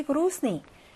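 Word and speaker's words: 그로스니 (грустный): A가 안드레이야 빠치무 왜 뜨있다 꼬이 그로스니 그로스니 하면은 우울한 슬픈이란 말이에요. 그리고 여기서 묵음이 하나 있거든요. 선생님이 어떤 거 발음 안 했죠? 내 발음을 하지 않았습니다. 너 왜 이렇게 슬퍼 보여?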